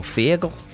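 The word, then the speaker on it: Feargal: Right, I've got some Virgil Sharkey coming up for, is it Virgil or Fergal? – Fergal. I'm sure it's Fergal.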